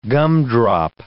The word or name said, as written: gumdrop